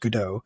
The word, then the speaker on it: Godot: It's kind of, it's spelled Godot, but I think it's actually pronounced Godot.